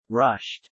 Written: rushed

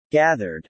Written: gathered

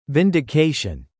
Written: vindication

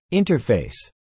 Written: interface

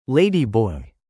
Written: Ladyboy

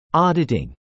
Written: auditing

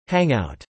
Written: Hangout

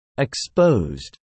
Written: exposed

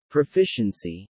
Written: Proficiency

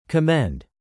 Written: commend

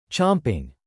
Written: chomping

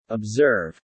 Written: observe

